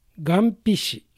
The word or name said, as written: Gampishi